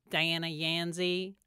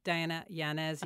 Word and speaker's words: Diana Yanez: They say Diana Yanzi. First thing. So I got to raise my hand and go, yeah, you mean Diana Yanez? Yes. It's actually exactly how it's spelled.